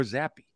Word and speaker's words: Zappe: Matter of fact, I had to ask my buddy, I go, how do you pronounce his last name? Is it Zapp or Zappe? – Right.